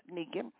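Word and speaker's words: Neakin: The artist is Neekin. That's spelled N-E-A-K-I-N. N-E-A-K-I-N.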